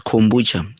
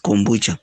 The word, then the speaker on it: kombucha: It's kombucha. You don't have to pronounce it in a different way. Just pronounce the way it is written. It's kombucha.